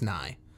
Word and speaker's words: Nighy: So I guess it is Nighy. – Yeah. – But it has a Y at the end, which is why I wanted to say it like that. It's N-I-G-H-Y. – N-I-G-H-Y. N-I-E.